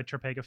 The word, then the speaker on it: Topeka: All right, I will go Topeka first. So great question. Firstly, you're pronouncing it right and like it's standard to say it wrong. Everyone says it wrong to start with.